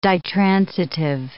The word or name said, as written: ditransitive